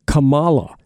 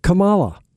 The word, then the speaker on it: Kamala: Her name is pronounced Kamala. Kamala, it's pronounced Kamala Harris.